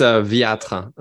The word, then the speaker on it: Wieter: It's Wieter. That's a European pronunciation. Wieter would be like the English pronunciation, but I'm from Europe, so I go with the European music.